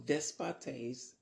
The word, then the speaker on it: despotes: So despotes, that's how you say Lord in the Greek. Despotes, that's how we say Lord in the Greek, apparently.